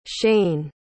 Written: cheyne

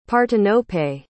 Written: partenope

